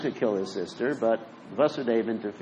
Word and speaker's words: Vasudeva: It's Vasudeva, it's the right pronunciation.